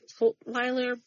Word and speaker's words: Tutwiler: So there's a 2022 article that says she's under lock and key at Julia Tutwiler Prison for Women. I think I said that right. Tutwiler? – That sounds, yes, that's it.